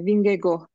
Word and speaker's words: Vingegaard: E, Vingego diye okunuyor ama hep okurken Vingegaard diye okuyorum ben.